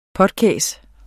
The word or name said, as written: potkæs